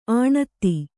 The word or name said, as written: ಆಣತ್ತಿ